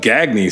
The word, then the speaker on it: Gagne: Whenever his last name was Gagne, G-A-G-N-E. But nope, his name was Gagne. That's how he pronounced it.